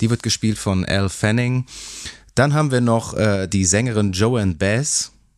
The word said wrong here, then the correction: Joan Baez: Wie wird die eigentlich richtig ausgesprochen? Joanne Baez? – Baez? Nee, es ist tatsächlich Joan Baez. – Joan Baez, vielen Dank, Uwe.